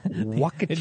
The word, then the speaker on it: Waukesha: Waukesha. – Just sit around by the Wee. – I don't know how we have all these international listeners. Waukesha does not sound like an American place.